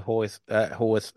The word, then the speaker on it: Haworth: sweeps through her village and she moves into Haworth and how is it pronounced? – Haworth. – I can never get it right.